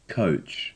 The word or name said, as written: coach